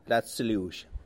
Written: Lats Liux

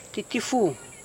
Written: titifu

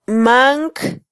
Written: manc

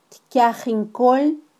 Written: tkyajinkol

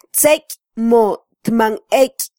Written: tzeky

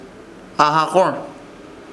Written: AHAKOR

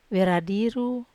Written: veradiru

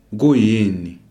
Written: kuyini

